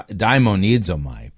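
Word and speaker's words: daimonizomai (δαιμονίζομαι): So his main argument that the the Greek word, by the way, he mispronounced it. It's da- daimonizomai, that it doesn't mean to be possessed by a demon.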